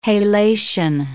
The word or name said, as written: halation